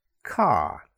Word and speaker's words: car: For example, car. I bought a new car. So it sounds like K-A, car, not C-A-R, car.